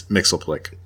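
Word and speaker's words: Mxyzptlk: So Johnny sets out to investigate and discovers that from the fifth dimension has come this little imp guy named Mixius Piddalek. And he and Mixius Piddalek go around, and Mixius Piddalek, he's like this mischievous guy. – Excuse me. It's Mixilplick.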